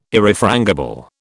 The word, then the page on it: Irrefrangible: British English